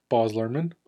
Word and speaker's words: Luhrmann: Baz Luhrmann.